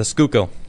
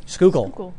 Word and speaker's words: Schuylkill: Roto says, I social distance via boat in the middle of the, how do you pronounce that? Schuylkill? – The Schuylkill. – Schuylkill. Ah, Schuylkill.